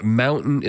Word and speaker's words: MNMTN: Mountain Lion, and Mountain is spelled MNMTN off of their debut Stealth, That's You Can Leave, But It's Going to Cost You in front.